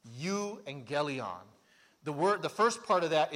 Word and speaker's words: euangelion: And in fact, this is the word where we get, if you've ever heard the phrase an evangelical Christian, because sometimes we pronounce the U as a V, so it'd be the evangelion or the euangelion.